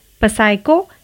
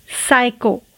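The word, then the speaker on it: psycho: पसाइको नहीं साइको साइको P S Y C H O साइको यहाँ पर पी साइलेंट है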